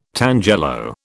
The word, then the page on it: tangelo: British English